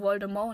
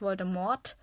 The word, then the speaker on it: Voldemort: Das T wird nicht ausgesprochen, also Voldemort und nicht Voldemort, sagen ja viele.